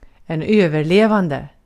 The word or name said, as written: överlevande